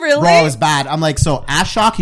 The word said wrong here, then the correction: Ashok: He's like, it's Ashok. I'm like, Ashok. Oh, okay, cool.